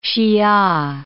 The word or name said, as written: Hsia